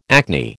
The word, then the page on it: acne: American English